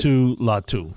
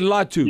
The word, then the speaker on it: Laiatu Latu: Latu, is that how you say it? – I did pretty good. Laiatu, Latu. – Okay, Latu.